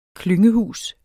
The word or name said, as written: klyngehus